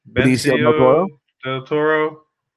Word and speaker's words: Benicio del Toro: Benicio del Toro.